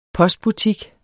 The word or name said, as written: postbutik